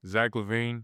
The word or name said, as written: Zach LaVine